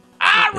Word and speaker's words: Irem: Irem.